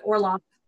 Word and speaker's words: Orlov: But otherwise, but yeah, so yeah, I'm enjoying it and I'm loving the actor who's playing, I think they call him kind of Orlo instead of Orlov here. – Yeah, there's a variation in how it's spelled, but Orlov.